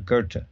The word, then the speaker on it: Goethe: And Goethe is spelled G-O-E-T-H-E, I believe, there. Mm-hmm. And I never could figure out how they got Goethe out of that.